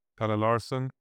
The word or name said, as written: Pelle Larsson